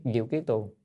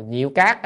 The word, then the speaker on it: Diệu Kiết Tường: Cho nên đọc là, nếu mà mình đọc là Diệu Cát á, thì Văn Cát á, thì nó không hợp với chữ Diệu Kiết Tường.